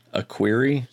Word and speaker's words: Equerry: Equerry. – A query? – Yes.